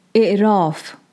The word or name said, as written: Aᶜrāf